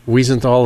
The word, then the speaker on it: Wiesenthal: Wiesenthal, did I say that correctly, Center? – Yes.